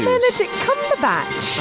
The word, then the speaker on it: Benedict Cumberbatch: Benedict Cumberbatch. Oh, my God, I said it right.